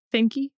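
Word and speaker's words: Fikre: It should just say, see the facts of FBI versus, how do you pronounce it? Fiker, Fikey, whatever. – No, Finky.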